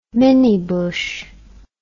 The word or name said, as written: minnie bush